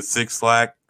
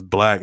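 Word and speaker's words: 6LACK: Yeah, it's like six LACK, and he gets mad on Twitter because people pronounce it Six Slack, and he's like, bro, it's not, it's Black.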